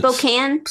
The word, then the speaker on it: Spokane: It's submitted by JJ van der Put from Sp- uh, How do you say this again? Spoken. Spoken. – Isn't it Spokane? Spokane. – I Sp- No, it's Spokane. It's not Spokane. – Spokane. Spokane, Washington.